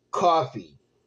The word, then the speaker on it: Coffey: The men's match was Trick Williams against Joe Coffey. Pronounced like the drink, but spelled differently.